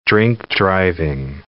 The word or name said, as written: drink-driving